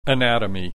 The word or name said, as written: anatomy